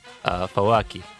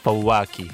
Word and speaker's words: fawaki: How do you say zibdiya with fruit? – Fawaki. – Falaki? – Fawaki. – Fawaki.